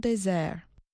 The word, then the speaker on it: Desert: He named the island, in French, Île des Monts Déserts, or Island of Desert Mountains. Today, locals still say the word desert similar to its French pronunciation, désert.